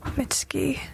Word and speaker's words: Mitski: Mitski. Her name is Mitski, M-I-T-S-K-I.